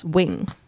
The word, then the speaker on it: Nguyen: Next up, this one is from Bindi Nguyen. – Apparently that's spelt, that's pronounced Wing.